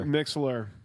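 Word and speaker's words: Mixlr: Mixler. You always fuck it up. – What? Wait. That R doesn't deserve pronunciation. That's just a fucking. That's and where's the L? We're just leaving the L out or like. – It's M-I-X-L-R.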